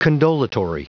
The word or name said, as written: condolatory